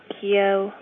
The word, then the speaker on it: Kyo: If someone needs a little help, I usually tell them my name rhymes with Rio. Kyo, Rio, you know, as in Brazil.